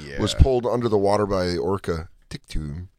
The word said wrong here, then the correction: Tilikum: How do you say that name? – Tilikum.